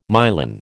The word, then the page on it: myelin: American English